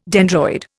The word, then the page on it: dendroid: Indian